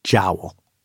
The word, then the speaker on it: Joule: The joke's on him and his heirs, though, because he pronounced his last name Jowl.